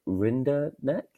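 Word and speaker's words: Rinderknech: I mean, looked like he was cruising against Arthur, I'm going to say Rinderneck. I'll take that. It is a silent K.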